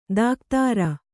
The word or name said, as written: ದಕೋದರ